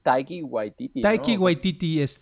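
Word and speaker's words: Taika Waititi: Waika Waititi o algo así, el director de Thor. – Taiki Waititi, el director divertidísimo.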